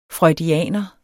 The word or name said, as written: freudianer